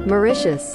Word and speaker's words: Mauritius: Mauritius.